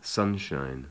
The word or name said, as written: sunshine